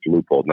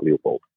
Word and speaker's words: Leupold: And he said also that we pronounced Leupold wrong. It's Leupold, not Leupold. So we got to sort our shit on that one.